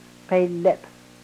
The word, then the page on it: pelep: Yurok